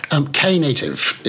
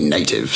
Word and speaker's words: Knative: Knative is the official pronunciation. Fun fact, it was, for a while, the official pronunciation was going to be native, silent K.